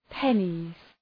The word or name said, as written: pennies